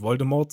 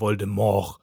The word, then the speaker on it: Voldemort: Laut J.K. Rowling wird der Name französisch ausgesprochen und im Französischen wird die Endung nicht ausgesprochen. Dementsprechend heißt er nicht Voldemort, sondern Voldemort.